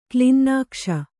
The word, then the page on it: ಕ್ಲಿನ್ನಾಕ್ಷ: klinnākṣna